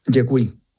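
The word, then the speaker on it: děkuji: ¿Y gracias cómo se dice? – Yekui. – Yekui. Eso se parece al asturiano.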